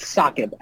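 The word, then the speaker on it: Saaqib: It's pronounced Saqib, actually. – Saqib, okay. – S-A-A.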